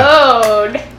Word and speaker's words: Bonehead: Because when they introduce Bonehead, they say emphasis on the first part. – Yeah. – Bone.